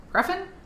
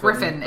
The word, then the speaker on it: Gruffydd: Gruffin? It sounded like Griffin. – It did That's sound- what I always thought it was Griffin. – Griffin. It might be because it's Welsh, and so therefore it's spelled weird.